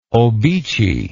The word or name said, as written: obeche